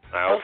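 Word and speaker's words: Iophia: Walter and Ofa, I-O-P-H-I-A. I don't know how to pronounce it. – Iofa.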